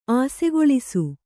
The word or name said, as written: ಅಸುಗೊಳಿಸು